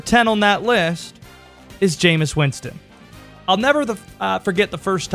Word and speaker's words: Jameis Winston: his name rhymes with famous. It's Jameis Winston.